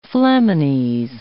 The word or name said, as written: flamines